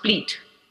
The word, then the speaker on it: Split: And then Croatia, it's written S-P-L-I-T, but it's not pronounced split. It's pronounced pleat.